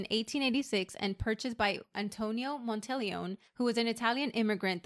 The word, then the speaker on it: Monteleone: It's an Italian word. Correct pronunciation is Monteleone, which I did not want to repeat like 100 times today. So I'm going to say Hotel Monteleone. I did call the hotel, by the way, just to make sure that was a correct pronunciation and that's how they pronounced it.